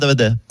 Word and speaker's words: Darmanin: Mon nom, c'est Gérald Darmanin. – Darmanin. Oui. – Darmanin, D-A-B-A-D-I-N.